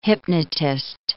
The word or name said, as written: hypnotist